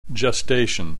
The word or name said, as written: gestation